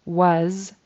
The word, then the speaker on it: was: So he says, so Sarah's, and then he ends was with an S instead of a Z sound. That S should be pronounced as Z. Was able to hold onto.